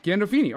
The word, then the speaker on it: Gandolfini: How do you actually say his name though? – Gandolfini. – Okay. I did say it right.